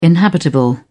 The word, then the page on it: inhabitable: British English